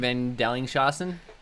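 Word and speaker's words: von Dellingshausen: You're crushing van Dellingshausen. Yeah, the other guy you're not killing very well. – No, how's it pronounced? – Yeah, you must have a little German in you mixed with a little Dutch. Yeah. You're doing great there.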